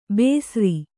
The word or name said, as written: ಬೇಸ್ರಿ